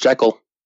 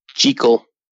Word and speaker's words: Jekyll: It's actually not pronounced Jekyll. – How is it pronounced then? – Jekyll.